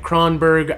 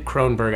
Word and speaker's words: Kronberg: Like Kronberg, I've accepted. Kronberg, I accept.